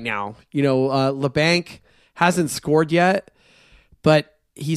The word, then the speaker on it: Labanc: Kevin Labanc factor now that we know how to say his name correctly. – It's Labanc. – It's Labanc. At least that's how I heard them say it on the broadcast.